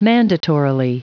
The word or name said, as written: mandatorily